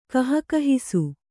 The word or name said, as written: ಗಹಗಹಿಸು